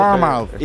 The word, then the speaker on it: Bournemouth: Bournemouth. Y no es Leicester, pues este es Bournemouth. – O sea, en inglés dicen Bournemouth.